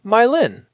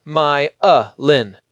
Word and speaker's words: myelin: Without this, some TTS engines will say myelin, rhyming with violin, which is wrong. The IPA forces the correct three-syllable pronunciation, my-a-lin.